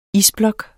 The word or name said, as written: isblok